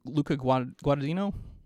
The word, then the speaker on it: Luca Guadagnino: They the director, I forget how to say it, Luca Guadagnino? – Yes.